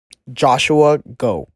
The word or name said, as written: Joshua Go